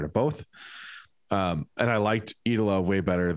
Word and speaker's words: Eidola: E-I-D-O-L-A. – Depends what emphasis you put on the syllable. – Yeah. Idola.